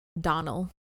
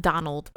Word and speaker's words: Domhnall: Also, Nick informed me that Domhnall Gleeson's name is actually pronounced Donnell. – Donnell? – Mm-hmm. Not Donald, but Donnell. – Donnell? Mm-hmm. Not Domhnall? – Yep.